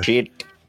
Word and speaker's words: Pschitt: That shit, for those of you listening, is spelled PSCHITT exclamation point. – Yep, silent P, baby. – Shit. – Like shit. Like psychology or anything else, you know.